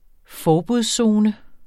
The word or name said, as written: forbudszone